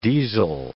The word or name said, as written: deasil